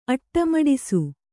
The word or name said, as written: ಅಟ್ಟಮಡಿಸು